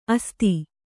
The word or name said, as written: ಅಸ್ಥಿ